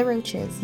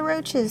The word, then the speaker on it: Roches: That was Runs in the Family by The Roaches. Yes, that is the pronunciation. The Roaches, three sisters from New Jersey.